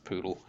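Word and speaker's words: PoDL: It's pronounced poodle.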